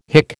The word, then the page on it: hick: American English